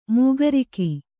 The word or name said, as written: ಮೂಗರಿಕಿ